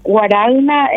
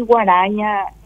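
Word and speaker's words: Guaragna: Sí, Guaraña en castellano y Guaraña, pronunciando correctamente en italiano.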